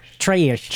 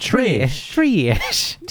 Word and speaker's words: Traysh: That's Traysh. – Traysh. T-R-A-Y-S-H.